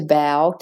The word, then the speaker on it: bow: So, for instance, B-O-W can be pronounced bow, as in bending over to bow to somebody, or bow, as in a ribbon in your hair tied in a bow. So bow, bow, spelt exactly the same. Row, so R-O-W can be said with the ow, row, which means a fight.